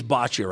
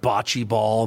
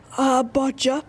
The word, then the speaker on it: boccia: If, you know, some of the more senior folks out there would be familiar with lawn bowling, but in boccia circles, it is boccia, right? It's not bocce ball. People say that wrong all the time, right? – Yeah, it's boccia, I-A. There's an I-A at the end. It's not B-O-C-C-E, it's B-O-C-C-I-A.